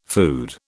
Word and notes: food: British English

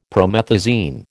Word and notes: promethazine: American English

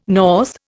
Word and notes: north: Indian

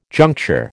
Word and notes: juncture: American English

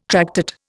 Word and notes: tractate: Indian

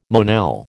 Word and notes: Monel: American English

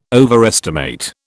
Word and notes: overestimate: British English

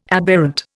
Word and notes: aberrant: Indian

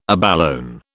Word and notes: abalone: British English

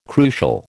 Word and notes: crucial: American English

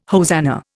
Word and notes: hosanna: Indian

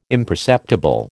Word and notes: imperceptible: American English

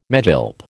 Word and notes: megilp: American English

